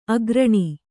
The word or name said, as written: ಆಕರಣೆ